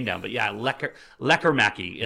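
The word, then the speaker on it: Lekkerimäki: I thought it was Lekker Mäki. – Yeah, so... – And the entire time they've been saying Lekkermäki. I'm like, you're wrong, man. Say it right.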